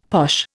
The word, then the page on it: posh: Indian